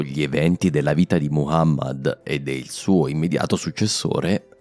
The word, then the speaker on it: Muhammad: La prima è proprio il nome più comune di tutti, Muhammad e non Muhammad. A quanto pare ho preso la pronuncia francofona del suo nome, scusate.